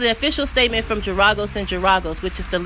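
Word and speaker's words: Geragos: So, Kaepernick's attorney, Mark, oh God, I'm going to butcher his last name, Giragos? Oh no, I think I said it right. Mark Giragos and Ben, oh, that's a tough one.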